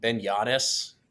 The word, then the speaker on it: Yanes: Spelled Yanes. So if you have to, phonetically do that on your own, spelled Yanes.